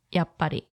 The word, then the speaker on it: やっぱり: Finally, the last thing you need to know is about different ways you can pronounce yappari. One is yahari and is the more polite form, usually used by service staff or when talking to someone of a higher social rank than you, slash someone older.